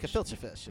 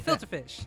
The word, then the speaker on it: gefilte fish: Because that's how Chris Tucker was trying to pronounce gefilte fish. – Gefilte fish. It's filter fish. – It's a filter fish. Yeah. No, it's gefilte fish.